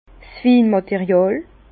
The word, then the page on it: 's Winmàteriàl: Bas-Rhin; Herrlisheim